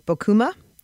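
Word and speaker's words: Bocuma: Bokuma.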